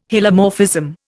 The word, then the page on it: Hylomorphism: Indian